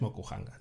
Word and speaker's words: Mokuhanga: Em, hemos dicho que el tema del que quería hablar era Mokuhanga. – Eh, era impronunciable, efectivamente. – Es Mokuhanga, no, no, te preocupes.